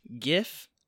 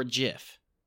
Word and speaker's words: GIF: Is it pronounced GIF or JIF? – Woo. That's big. – Yeah. That's tough. – I'm coming out of the gates and I'm saying JIF. – You're saying JIF? – Yeah, JIF. – I've always been a GIF guy.